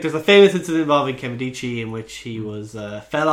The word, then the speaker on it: Nkemdiche: Well, he was implicated in the event associated with the defensive line prospect Nkemdiche. – Silent N. No, it's never a silent N.